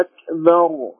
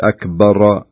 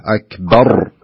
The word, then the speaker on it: أكبر: أول كنت يعني قلت أكبر أكبر، أيوة، صار فيه شوي من القلقة له في الراء، والمفروض أنها يصير فيها تكرار لكن ليس قلقة له، أكبر بهذا الشكل.